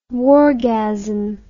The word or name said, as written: wargasm